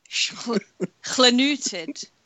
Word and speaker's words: Llanwrtyd: Llanwrtyd, Llanwrtyd, Llanwrtyd Wells.